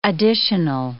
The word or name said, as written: additional